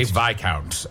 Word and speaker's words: Viscount: I always say Viscount. I think that's probably right. Like to confuse people and remind them that S is silent.